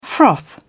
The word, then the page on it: froth: British English